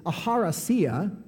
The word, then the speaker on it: eucharistia: It's actually, the way you pronounce it is aharasia, but the way it's spelled, check this out, is E-U-C-H-A-R-I-S-T-I-A. If I was just going to pronounce it, I would say eucharistia.